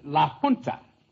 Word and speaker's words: La Junta: In Spanish, you pronounce the J like an H. It's Navajo and La Junta.